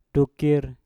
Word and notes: dukir: Mpur

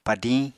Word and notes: padiñ: Apatani

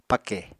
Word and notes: pake: Apatani

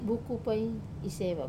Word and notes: buku pai iseva: Wamesa

